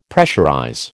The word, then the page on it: pressurize: American English